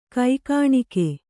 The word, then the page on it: ಕೈಕಾಣಿಕೆ: kai kāṇike